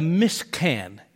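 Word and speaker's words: mishkan (משכן): It's called a mishkan, is what it's, that's how you say it in Hebrew.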